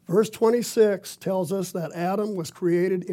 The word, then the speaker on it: Adam: The word is pronounced Adam. The Romanized spelling is A-D-A-M or Adam.